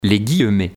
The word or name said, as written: Les guillemets